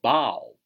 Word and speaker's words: bough: 在木炭烧烤炉里点上火，这我们学熟悉的，呃，熟悉的第三个单词，熟悉的第三个，呃，第四个单词啊，熟悉的第四个单词叫名词大树枝啊，名词大树枝读作 bough 啊，bough，b o u g h，b o u g h，bough，名词大树枝，这个单词也很好记，bough，b o u g h，它的谐音抱，抱着嘛，抱着大树枝。啊，你可以这么想象，说这个树啊实在太大了，就连它的树枝我一个人都抱不过来。